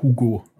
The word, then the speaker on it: Hugo: der Hugo, aber nur französisch ausgesprochen hat.